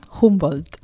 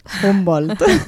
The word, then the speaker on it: Humboldt: Ho hauré pronunciat malament, no? – Humboldt. – Humboldt.